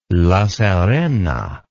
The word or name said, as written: La Serena